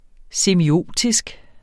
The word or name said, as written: semiotisk